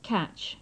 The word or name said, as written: catch